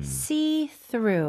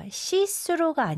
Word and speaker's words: see-through: See-through. – 오. – 영어로도 이렇게 쓰는데. – See-through. – 네. 근데 발음은 주의하셔야 돼요. 시스루가 아니고 see-through bangs.